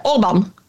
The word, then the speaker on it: Orbán: Och som halvungrare, Orbán. – Orbán. – Herregud. – Du menar, för det är alltid Orbán valsegrare.